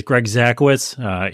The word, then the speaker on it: Zakowicz: So my name is Greg Zakowicz. Easy pronunciation there. Looks worse than it is.